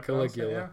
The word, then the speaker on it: Caligula: I'm trying to think of if it's Caligula. – Oh yeah, that's it. That's how you say it. Caligula. Caligula. Sounds better.